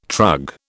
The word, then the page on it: trug: British English